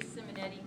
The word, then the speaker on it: Simonetti: Marie Simonetti Goodwin. It's M-A-R-I-E-S-I-M-O-N-E-T-T-I and G-O-O-D-W-I-N.